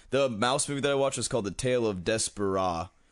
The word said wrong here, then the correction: Despereaux: I think that's how you pronounce it. – Not Desperado? – No, it's like Despereaux.